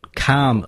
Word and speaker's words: calm: Calm, like a nice calm day. – I taught you like calm yourself. – Oh yeah, it's the same. Yeah, and but that has a silent letter as well, but it's a silent L. It's C A L M.